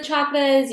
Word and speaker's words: chakra: that you pronounce it chakra. It is not chakra. That is a colonialized way of saying the word.